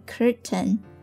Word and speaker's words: curtain: 我承认那这一句对应的是浪费时间是我情愿浪费时间是我情愿 I'm wasting my time, yeah, I'll admit 好了下一句像谢幕的演员眼看着灯光熄灭首先这个单词 curtain C-U-R-T-A-I-N 它表示窗帘在这里呢是指幕布的意思我们要说一下这个词的读音它读作 curtain 但是呢在美音里面呢你可能会听到人家这么说 curtain 这个词的发音呢就类似于 Britain 其实呢很多人他并不会把它说成是 Britain 而是说 Britain